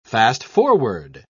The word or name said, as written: fast-forward